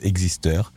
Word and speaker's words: Exister: Alors, en fait, il pensait à la base qu'il inventait le mot et ça, se prononce pas du tout à la française, ça se prononce existeur.